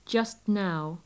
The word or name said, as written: just now